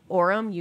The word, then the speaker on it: Orem: Art is the founder of Amano Chocolate, a bean-to-bar chocolate manufacturer based out of Orem, Utah. Did I say that right? – It is right.